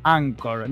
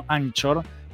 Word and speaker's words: Anchor: Se llama Anchor FM. Se pronuncia así, Anchor, no Anchor, que es una anchoa y es lo que están diciendo todos y está mal.